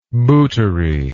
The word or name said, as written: bootery